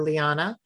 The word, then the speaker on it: Liana: I am at Valor Liana, L-I-A-N-A.